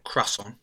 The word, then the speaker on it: croissant: It is croissant. It's French. So, croissant.